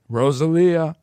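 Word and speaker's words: Rosalía: Rosalia.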